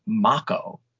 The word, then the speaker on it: Mako: I'm now being told that it's pronounced Mako. – Oh. – Now, we've been saying it wrong all these years. Apparently, it is pronounced Mako.